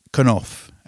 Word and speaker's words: Cnof: And from that compilation, this is an artist I've never heard of before called Knof. At least it's spelled C-N-O-F.